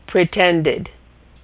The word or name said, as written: pretended